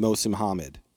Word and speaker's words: Mohsin Hamid: And he pronounced his own name Mohsin Hamid.